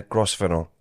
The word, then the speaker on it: Grosvenor: And your friend is in the hot seat today, Edwina Grosvenor. Is that how you say your second name? – Yeah, you can say it like that. Yeah. Grosvenor, Grosvenor. Yeah.